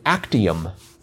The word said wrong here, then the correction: actinium: Oh, I'm sorry. It's actinium. Wait, now I can't even pronounce this. Actinium. Actinium. Not actium. Just actinium.